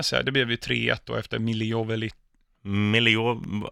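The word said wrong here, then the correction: Milivojević: Milivojevic heter han.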